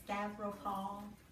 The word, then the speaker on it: Stavropol: Being in the city of Stavropol, and am I saying that right? Stavropol, Stavropol. Stavropol. Not too far off.